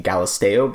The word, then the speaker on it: Galisteo: I call it the Galisto Basin when it is the Galisteo Basin, as well as Tuyoni is actually Kuene.